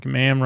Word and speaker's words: ma'am: Something like Ma'am rhymes with Ham. not ma'am, rhyme, mar, mom, farm. I watched a documentary where they said it was ma'am with am.